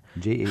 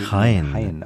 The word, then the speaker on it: Jaén: It's, okay, and it's, can you spell that for us? – Yes, of course, it's J-A-E-N. – Jaén. – Jaén, okay.